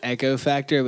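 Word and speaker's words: Eco Factor: It's Echo Factor, but it's spelled E C O Factor.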